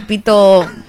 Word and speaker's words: pito: It's pito. – It is. Mabuhay. – No. Pito.